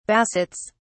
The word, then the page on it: bassets: British English